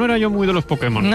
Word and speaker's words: Pokémon: porque nadie como él para pronunciar ciertos nombres. – Para permitir que Putemon. – El poder que hay en Pokémon. – Putemon. – Hazte con solo. – Pues hazte con todos. Pudemon. – Te diré que es difícil decirlo peor.